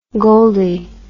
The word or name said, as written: goldie